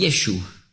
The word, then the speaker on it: Jeschu: Aber sie sagen nicht Jeschua, sondern Jeschu. Also Jeschu schreibt man auf Hebräisch Jod, Shin, das ist bei uns SCH, und ein Vav, ein V. Das wird ausgesprochen als U, das V.